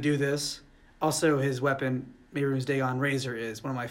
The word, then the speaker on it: Mehrunes Dagon: I have the pronunciation right there. – Mehrunes Dagon. – Yeah, there you go.